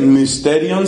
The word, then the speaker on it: mysterion: Ese mysterion se escribe con la y que se pronuncia como la I francesa, entonces mysterion se volvió sacramentum.